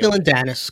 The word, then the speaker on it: Dillon Danis: Well, actually, he did following his boxing fight against uh, Dillon Danis. I think I said that right. I don't know if I did. Romeo Dillon Danis, close enough.